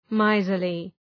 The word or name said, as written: miserly